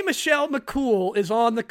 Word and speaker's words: Michelle McCool: It's not Michelle McCall. It's Michelle McCool. – How do you say it? Michelle McCool. – Michelle McCool. – Michelle McCall. – Yeah, no, I say Michelle McCool. That's how you say cool.